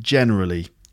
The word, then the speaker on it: generally: Just the word generally. So again, generally is on the first syllable too. So the way I generally introduce. So not generally, but generally.